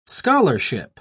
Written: scholarship

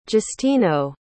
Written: justino

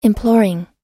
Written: imploring

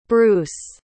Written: bruce

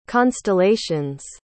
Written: constellations

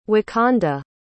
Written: wakanda

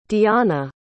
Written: deona

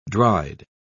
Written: dried

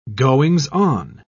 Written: goings-on

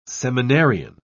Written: seminarian

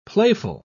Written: playful

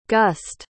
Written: guste

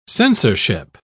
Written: censorship

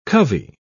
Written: covey

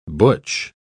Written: butch

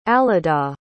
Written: alyda

